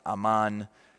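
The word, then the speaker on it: aman (אמן): And depending how the vowels show up, the way it's conjugated, here it would be pronounced aman, but it is the same word we hear, amen. That is not an English word.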